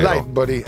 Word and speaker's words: Lightbody: Lightbody. Ah, mi correggi l'accento adesso.